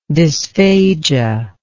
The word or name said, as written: dysphagia